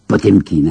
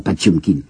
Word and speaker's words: Potemkin: Nous prononçons Potemkin, hein, cuirassé Potemkin, paraît-il qu'il faut prononcer ça Patchumkin.